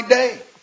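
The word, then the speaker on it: day (δεῖ): It's just a little three-letter word, D-E-I. It's pronounced day in the Greek, and it describes a divine imperative.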